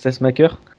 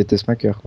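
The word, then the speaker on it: Tessmacher: ça se prononce Testmacher. – Testmacher oui. – En oh, merde. – Il sera pour la suite. – Testmacher. – Oui c'est comme le pacemaker, c'est Testmacher.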